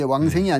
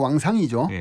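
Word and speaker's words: 왕상 (王祥): 예, 이건 이제 사투리 발음인데 왕생이 네. 아니고 왕상이죠.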